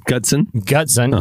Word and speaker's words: Gutzon: Are you sure you said that right? – I'm gonna spell it for you. Gutzon. That's his first name. – Gutzon. – Uh huh. And then Borglum. Borglum. – Yeah, Borglum. Yeah. – So anyway,